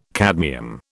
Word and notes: cadmium: British English

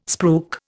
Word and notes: spruik: Indian

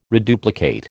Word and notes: reduplicate: American English

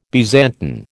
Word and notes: Byzantine: American English

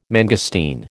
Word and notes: mangosteen: American English

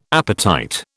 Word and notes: appetite: British English

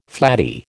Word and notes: flattie: American English

